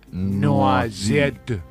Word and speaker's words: noisette: Se pronuncia, se escribe noisette, sí, pero en francés se pronuncia noisette.